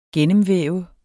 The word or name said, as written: gennemvæve